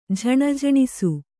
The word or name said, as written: ಝಣಝಣಿಸು